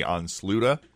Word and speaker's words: ansluta: A-N-S-L. – That looks like Onslut. – Yeah. Well, in Swedish, I'm guessing that's the pronunciation. Mm. A-N-S-L-U-T-A, Onsluta, which is Swedish for connect.